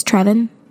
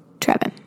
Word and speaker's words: Trevin: Somehow I feel like that can't be how you pronounce his name. I'm sure I'm going to figure it out one day. But Trevin Wax. That's how it is. Oh, it is Trevin? Trevin. Yes, he wrote a book that Ashley was reading that she learned about in the Gospel Coalition.